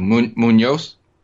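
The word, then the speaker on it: Muñoz: Yeah, the Spanish Z is pronounced like a soft C in front of the letter E or I, for example. For example, like azul. Azul. It's not Z, it's azul. – So is it like Munoz? – Yeah.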